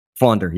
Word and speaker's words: Fonder: Ryan Fonder, not Fonder. – Okay, Fonder. – Fonder, yes. – All right. Like fawn, like a dappled deer. – Correct. – Like Ryan Fonder.